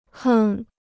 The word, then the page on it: 哼: hēng